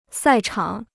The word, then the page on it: 赛场: sài chǎng